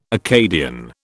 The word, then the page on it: Acadian: British English